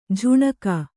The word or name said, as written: ಜುಣುಗು